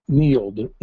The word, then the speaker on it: Neild: Field, I'm sorry, I think I totally butchered that. Um, but they were pointing out, and it's, it's really kind of shocking, really. Neild, N E I L D, Neild versus District of Columbia.